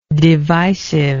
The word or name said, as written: divisiveness